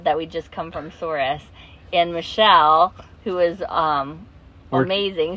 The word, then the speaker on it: Souris: And we thought it was Cerise. – Souris.